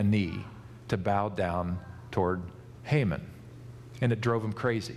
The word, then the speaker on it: Haman: Time went on, and this really bad guy named, in Hebrew it would be called Haman, but in English you hear his name pronounced Haman.